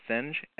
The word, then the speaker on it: Singh: This is the one from Simon, I think you say his name is Singh, S-I-N-G-H.